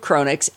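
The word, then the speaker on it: Cronnix: A quick download of Chronix, and we had a tool that was so simple to use, even I could figure it out.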